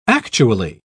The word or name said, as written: actually